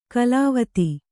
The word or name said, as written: ಕಲಾವತಿ